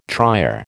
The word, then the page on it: trier: American English